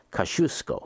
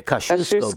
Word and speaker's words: Kosciuszko: Kosciuszko. – Yeah. – The Kosciuszko Bridge. Yeah, Kosciuszko.